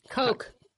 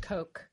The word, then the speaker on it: Koch: The Cox brothers or Koch. – Koch brothers. – It's pronounced Koch.